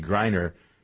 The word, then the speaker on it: Griner: He's working on trying to get Brittany Griner, that's how we pronounce it.